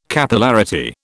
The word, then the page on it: Capillarity: British English